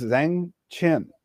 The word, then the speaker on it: Zhang Qian: One of the very first ones, Zhang Qian. I listened to pronunciations earlier because it's Q-I-A-N. – Q-I-A-N. Okay. – You pronounce it like Ch.